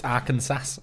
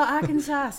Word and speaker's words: Arkansas: It's Arkansas. – Oh, Arkansas. Yes, okay. – Yeah, okay. – So back in the day, in the mid to late 1800s in Arkansas, they rented criminals to work on big farms.